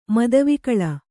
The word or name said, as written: ಮದವಿಕಳ